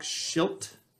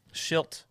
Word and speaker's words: Shildt: Mike Schilt. – Schilt.